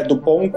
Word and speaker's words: Dupont: No Brasil eles vieram com o original que foi lá de fora, do original mesmo, que é Dupont e Dupont. – É Dupont com T e Dupont com D, mas você não pronuncia a letra final, então...